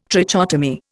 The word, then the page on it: trichotomy: Indian